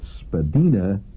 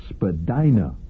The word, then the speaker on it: Spadina: Spadina is pronounced Spadina below College Street and Spadina above College Street.